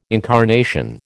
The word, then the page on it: incarnation: American English